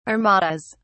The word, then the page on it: armadas: British English